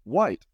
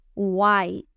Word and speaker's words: white: White. White. Aquí, ¿qué ocurre? La W y la H. W y H suenan como U, U, U. La I suena con el nombre que es I, I, White.